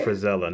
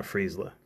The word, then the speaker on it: Frazella: I definitely pronounced his name wrong. – That's all right. – Frazella, not Friesla.